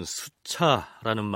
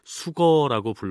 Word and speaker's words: 수거: 그런데 성신여대 오종록 교수는 조선시대에는 이것을 수차라고 하지 않고 인력거, 자전거 하는 식으로 수거라고 불렀을 것이라고 얘기합니다. 그래 왔다가 우리나라에 전차가 처음 생기면서 이 술의 거자를 차자로 발음하기 시작하면서 전차, 기차, 자동차 그리고 수차 이런 식으로 부르게 됐다는 것이죠. 따라서 세종실록에 올라 있는 물술의 경우에는 수거라고 해야 옳겠습니다만 우리 프로그램에선 사람들이 흔히 쓰는 대로 수차라고 칭하겠습니다.